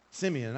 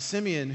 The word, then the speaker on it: Simeon: who is at the Simeon. I said it right the first time. Simeon,